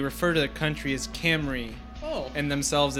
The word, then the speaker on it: Cymru: I thought it was Simru. – Simru, but I looked it up. It is actually pronounced Camry. – Okay. – C-Y-M-R-U is actually Welsh.